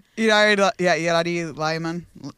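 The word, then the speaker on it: Iyari Limon: But for the record, in 2003, Yari, how do you say her name? – Yari Lyman. Lyman, sorry, Yari. Yeah, Lyman.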